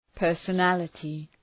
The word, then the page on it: personality: ,pɜ:rsə’nælətı